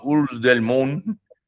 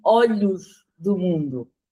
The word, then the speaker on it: Olhos do Mundo: Olhos del Mundo. – That is Catalan, not Portuguese. – Oh, okay. – Yeah. In Portuguese would be Olhos do Mundo.